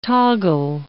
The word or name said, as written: toggle